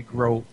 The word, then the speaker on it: groat: No, it's spelled literally groat. – G-R-O-A-T. – Goat with an R.